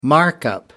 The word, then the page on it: markup: American English